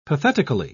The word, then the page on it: pathetically: British English